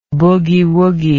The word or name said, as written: boogie woogie